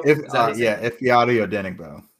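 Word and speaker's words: Ifeadi Odenigbo: You're going to have who's the guy that you always get the pronunciation right? Ifeati Odenigbo? – Ifeati Odenigbo. – Yes.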